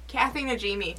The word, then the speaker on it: Najimi: It's Najimi. Kathy, how do you say her last name? – Kathy Najimi.